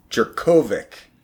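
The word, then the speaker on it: Jurkovec: I did some Googling on Phil earlier today, and I found out they pronounce his name Jurkovic with the hard co.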